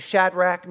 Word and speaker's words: Shadrach: It's about three guys named Shadrach, Meshach, and Abednego. And that's really how you say it.